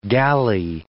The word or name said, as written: gally